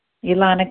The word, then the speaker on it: Elana: Ilana K. – Ilana. How do you spell that, Ilana? – E-L-A-N-A.